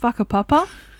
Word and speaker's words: Whakapapa: I'd like to preface this with a WH in Māori is an F phonetically, so it is called Whakapapa, which I didn't know until halfway through the hike and someone had told me.